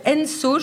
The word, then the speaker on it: Enso: Euh, euh, Enso, je crois que ça se prononce point Paris, donc E-N-S-O point Paris.